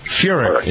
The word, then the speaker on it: Furyk: Is it Furyk? Is that how you pronounce his name? – Furyk, yeah.